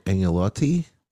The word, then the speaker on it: agnolotti: They also have a gorgonzola cheese-filled agnolotti.